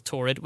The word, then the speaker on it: Taured: They took him into a wee room and they were like, look, it says here if you're from a place called Torrid, which is spelled T-A-U-R-E-D.